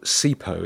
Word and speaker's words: Sipho: There's a guy called Sipho. It's spelled Sipho, S-I-P-H-O, who is an R&B singer from Birmingham.